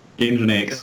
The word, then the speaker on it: Nginx: Nginx.